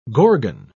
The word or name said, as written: gorgon